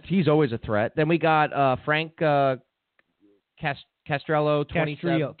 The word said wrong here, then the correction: Castrillo: You don't pronounce the double L. – He's a local, right? – It's a Y when you do it in Spanish.